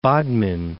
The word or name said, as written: Bodmin